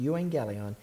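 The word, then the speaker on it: euangelion (εὐαγγέλιον): to use the word euangelion. That's how it's pronounced, euangelion.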